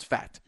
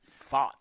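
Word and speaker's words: Pfaadt: Where is he right now in his recovery, and where's his arm ability facing – Brandon Fott, who we could pronounce fat. – Fought. – Because he's fat. – Fought the Lord. – Brandon Fott. – Fought. I like that. – P-F-A-A-T.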